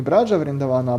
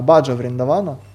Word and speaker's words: Баджа: Вриндаван и Брадж — это одно и то же совершенно место, поэтому не Браджа Вриндавана, а Баджа Вриндавана.